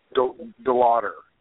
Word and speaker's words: Delauder: Delauder, with a D.